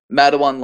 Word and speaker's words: Matewan: Madawan, like the where there was the famous coal strike and led to basically like a mini labor war.